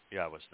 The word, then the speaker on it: Yahweh: Yahweh, the proper pronunciation of the name of Eloah, God, as directed in his Torah teaching regarding his Haya existence and our Shalom restoration. If you can say Eloah, Torah, Shalom, and Haya, then you can pronounce God's name.